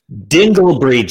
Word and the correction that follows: Dingleberry: You said Dinglebury. Dinglebury. What's up with that? That's Dingleberry, dude.